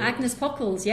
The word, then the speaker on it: Agnes Pockels: I'm going to pronounce her name wrong, I'm sure. Agnes Pockels. – Agnes Pockels, yes.